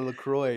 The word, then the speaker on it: LaCroix: I think it is French because French, you don't usually pronounce the last letter in like in the word. So LaCroix.